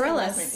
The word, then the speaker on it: Bareilles: Bareilles.